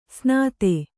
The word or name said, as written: ಸ್ನಾತೆ